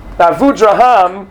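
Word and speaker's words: Abudarham: Avudraham, we all pronounce it Avudraham. Even later Rishonim pronounced his name Avudraham. He was a 14th century Rishon. And many people scholars more pronounce his name Avudraham. But we all call him Avudraham.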